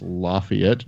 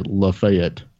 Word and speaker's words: Lafayette: Where I'm from in Chattanooga, there's what is should be pronounced Lafayette, Georgia, but the natives say Lafayette.